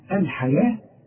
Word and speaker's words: الحياة: اشتروا الحياة الحياة مكتوبة بالواو ولكن تنطق بالالف ورسم لك الف فوق الواو بيقول لك تنطقها الف كامله الحياه لكن اذا كتبتها تكتبها بالواو.